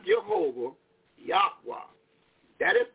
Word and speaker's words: Yahweh: And how you pronounce it, one of them big scholars, they did pronounce it in that 30-minute lecture they gave, Yahweh. And that is correct. – Yahweh.